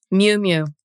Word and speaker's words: Miu Miu: Miu Miu. – Miu Miu.